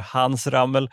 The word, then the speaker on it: Hans Rammel: Där har en 43-årig fideikommissarie, Hans Rammel, han uttalar Hans Rammel, tagit över.